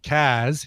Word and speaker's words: Cars: And would love to hear a cover of my favorite band, The Cars, pronounced Kaz, here in Boston.